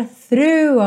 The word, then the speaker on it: through: T-H-R-O-U-G-H. O g h do final não é pronunciado. Então pense nela through through, ok? Th, th, th, linguinha lá na pontinha do dente, through, e você já volta então puxando o R, through, through.